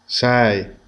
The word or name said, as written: 6